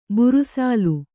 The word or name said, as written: ಬುರುಸಾಲು